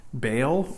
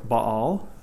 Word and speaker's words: Baal: And so often in the life of Israel, especially when they began to worship Baal or Baal, for those who, of you who are attuned to the correct pronunciation of Semitic gods of the late Bronze Age, so often in the worship of idols in Israel's life was involved a sexual brokenness.